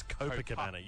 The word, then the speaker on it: Copacabana: It's Copacabana.